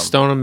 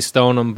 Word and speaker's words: Stoneham: We say Stoneham, but it's Stoneham.